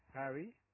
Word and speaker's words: Harry: My name is Harry, yep. – Harry Ali. – Yep, spell it for me. – H-H-A-R-R-Y, Harry. – Yep. – Ali. A-L-I.